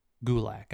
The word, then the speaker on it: Gulak: Or Gallic. How do you say his last name? – Drew Gulak. – Gulak. I was way off.